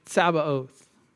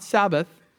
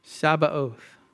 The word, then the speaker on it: Tsabaoth: So this is how you say it. And I'm really stretching out the ta here. And it's literally Tsabaoth. And in English, we drop the T and it just becomes Yahweh Tsabaoth. Not Sabbath. Sabbath doesn't have the O sound at the end there. Tsabaoth.